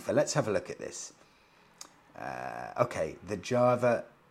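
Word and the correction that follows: Jawa: It's not Java, it's Yawa. Yawa, I keep getting the pronunciation wrong.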